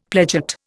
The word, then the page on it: pledget: Indian